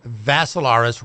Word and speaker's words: Vassilaris: It's Dimitri with three I's, D-I-M-I-T-R-I. Last name is Vassilaris, rhymes with Assilaris, but you add a V to it, and that way you'll...